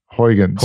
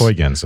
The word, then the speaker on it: Huygens: Is it Huygens? Is that what we say? – I always pronounce it Huygens. – Huygens, I think you're right.